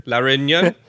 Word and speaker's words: Réunion: And that is that there's a brand out there in Réunion, or how you say it was, Réunion? Did I say that better? La Réunion? I got closer, I think. – Very nice.